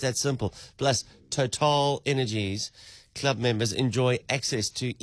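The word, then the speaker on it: TotalEnergies: Total Energies. I know it still sounds funny, but that's the correct pronunciation. – I like the way you say it. Say it again. – Total is French.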